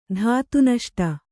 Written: ಧಾತುನಷ್ಟ

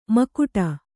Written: ಮುಕುಟೆ

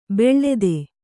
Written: ಬಿಳ್ತು

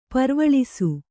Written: ಪರ್ವಳಿಸು

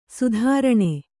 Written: ಸ್ತ್ರೈಣ

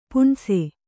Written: ಪುಣ್ಸೆ

